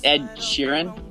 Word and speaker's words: Sheeran: My pick of the week is a song called "Drunk," and it's by an artist named Ed Sirin. – I'm still on the call. It's Ed Sheeran. You're pronouncing it pretty weird.